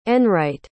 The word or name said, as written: enright